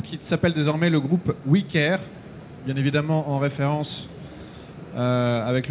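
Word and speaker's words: OuiCare: qui est écrit O-U-I pour maintenir cet aspect français, mais en même temps qui se prononce « oui » comme le « oui » nous, anglais, le « we care », parce que notre métier, c'est de porter attention.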